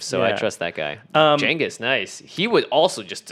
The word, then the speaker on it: Genghis: Genghis is the correct pronunciation.